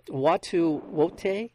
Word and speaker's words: Watu Wote: Um, Watu Wote, uh, or all of us.